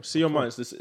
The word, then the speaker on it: Sion Martins: Sion Martins. There's a T in it, but it's silent, so you got it.